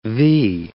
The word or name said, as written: vee